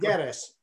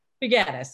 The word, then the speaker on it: Figueres: Figueres. – Figueres, thank you, not that hard.